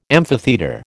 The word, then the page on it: amphitheatre: American English